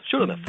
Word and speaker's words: Shulamith: He wrote a book called Kiss Me Like You Mean It, and it's based on Song of Songs, the words that Solomon and Shulamith. Do I say that right? Sometimes I get it messed up. – Shulamith.